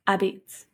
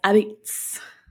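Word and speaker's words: apizza: So pizza in like Connecticut is spelt with an A at the beginning, so it looks like a pizza. – What? – But they pronounce it as abits.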